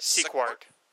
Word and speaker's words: Sequart: So my life, music, movies, a little comics here and there, but I've also been writing for a site called Sequart. How do you say that? – Sequart.